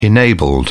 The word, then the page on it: enabled: British English